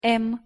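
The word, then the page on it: м: Russian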